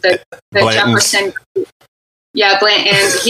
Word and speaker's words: Blanton's: Yeah, by the way, and you say it right. You said Blanton's.